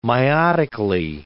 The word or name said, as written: meiotically